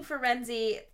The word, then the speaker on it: Firenze: Well, okay, and I'm taking Firenze. I got that pronunciation from Jim Dale. So that's, I just wanted to explain, that's why we say Firenze.